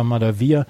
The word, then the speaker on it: Malivai: Malivai Washington, wie er geschrieben wird, aber Malavia, wie er ausgesprochen wird.